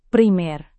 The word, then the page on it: primer: Indonesian